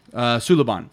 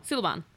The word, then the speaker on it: Suliban: Suliban. – Suliban. Oh, did not write it phonetically enough for me, I guess. – You're reading it more than you're hearing it.